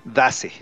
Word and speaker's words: Daze: y Days es D-A-Z-E. Dace.